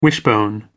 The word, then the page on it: wishbone: American English